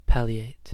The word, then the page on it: palliate: American English